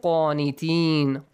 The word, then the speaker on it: qanitin: Waqumu lillahi qanitin. Qanitin. Pronounce it correctly with a heavy sound.